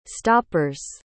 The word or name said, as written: stoppers